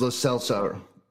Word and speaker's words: Lo Celso: it is pronounced Lo Celso.